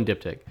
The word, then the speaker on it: diptych: Diptych.